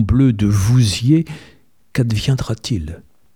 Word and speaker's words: Vouziers: Vouziers. Les gens du patelin prononcent ainsi « Voudsière ».